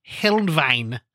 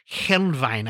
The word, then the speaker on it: Helnwein: Uh, he was heavily influenced by uh, Gottfried Heinlein. I'm Hel, Hel, Helnwein. Helnwein. I can't ever pronounce that. Gottfried Helnwein. – Helnwein.